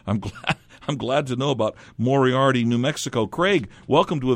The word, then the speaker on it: Moriarty: I hope I'm pronouncing it properly. Moriarty, New Mexico. Is that right? – Yes, that's correct.